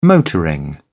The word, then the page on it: motoring: British English